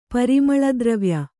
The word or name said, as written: ಪರಿಮಳದ್ರವ್ಯ